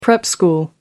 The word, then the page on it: prep school: American English